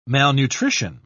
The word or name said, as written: malnutrition